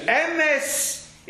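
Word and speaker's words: emes (אמת): As they say, emes is sheker, and sheker is emes. The correct pronunciation of the word for truth is emes, but it's an e'er word.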